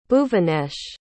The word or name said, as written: bhuvanesh